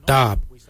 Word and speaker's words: dhaab: Today we will look at, for knife we say darb. Darb is spelled D-H-A-A-B.